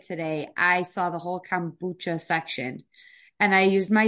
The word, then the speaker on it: kombucha: Kombucha, how do you say it? – Kombucha.